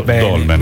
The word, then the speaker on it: Dolmen: Quindi, dopo i capelli, è una razza di cane il Dalmen. – Dalmen. – Capire come uno pronuncia, no? Perché effettivamente c'è questa pietra bianca Bollonini neri. – E questo è il significato del monumento Dolmen.